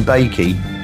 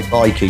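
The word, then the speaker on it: Baiki: No, I'm calling them Bakey. Might be Bikey. Yeah, it's Bikey. I think it's Bikey. It's Bikey. It's not Bakey. It's Bikey. I remember they sent me an email saying you pronounce as Bikey.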